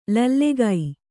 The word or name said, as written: ಲೋಲಕ್ಕು